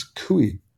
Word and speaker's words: Couy: More recently, though, a New Mexico County Commissioner, how do you pronounce his name? – I think it's Cooey. It's an unusual name. Cooey Griffin. – C-O-U-Y.